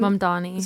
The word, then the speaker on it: Mamdani: Mamdani.